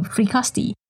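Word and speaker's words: Frescati: operation in Frikasti. Yeah, that's how they say it.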